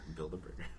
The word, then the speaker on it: Bilderberger: It's like Bilderberger, like Build-A-Bear. Bilderberger.